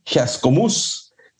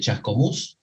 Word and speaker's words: Chascomús: de Whisky Chascomús. Si es que no lo, pronuncio correctamente, yo sé que es con acento en la U.